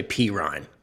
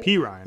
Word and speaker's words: Perine: I think it's, just so you know, I mean, I think it's Sasha Perine. That's how they say it on the TV. Right, but I'll say that. – Perine, right? – Perine.